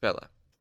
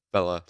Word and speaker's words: Fela: I'll try to make that a little less like fella, like, hey there, fella, and more, maybe fela, yeah. I'll add a little A in there, fela. Yeah, okay. Fela, she said.